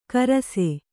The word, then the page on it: ಕಾರಸೆ: Kannada